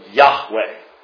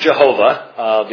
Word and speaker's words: Yahweh: Remember, Hebrew is read from right to left, so Yahweh. This is the Tetragrammaton. It could never have been pronounced Jehovah, because the Hebrew language didn't have those sounds, but in fact, Jehovah has an extra syllable.